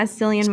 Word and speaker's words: Cillian: So I'm looking at it now and I want to tell you, Cillian Murphy, I think that's how you say his name, right? – Yeah, Cillian Murphy.